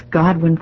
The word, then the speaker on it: Godwin: Gail Godwin, and I'm pronouncing it correctly. It is Godwin, not Goodwin. – It's Godwin. Thank you for pronouncing it right.